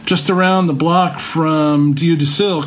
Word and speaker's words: Dieu du Ciel: But the one place you definitely have to go in Montreal is Diu du Ciel. It's spelled D-I-E-U-D-U-C-I-E-L.